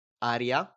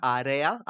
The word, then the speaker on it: area: Aria. – Yeah. – It's air in Italian. – Aria. – Area. Area. It's a E, not a I.